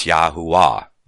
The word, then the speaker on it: Yahuwah: If you mounce over it, you look at the pronunciation given there, and you pronounce it rightly, it's Yahuwah. You realize that the V is not pronounced in the pronunciation there. We don't pronounce it with a V sound.